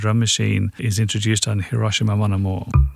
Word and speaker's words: Hiroshima: I think Japanese words are pronounced without any stress on any syllable, so it should be Hiroshima.